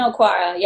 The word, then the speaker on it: Okwara: Did I do that right? Did I get his name right? – Julian Okwara, yes.